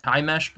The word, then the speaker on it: Himesh: I just, I'm sure Mr. Patel is, I don't know how to say his first, Himesh Patel, is that how his name's pronounced? – Yes.